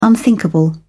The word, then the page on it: unthinkable: British English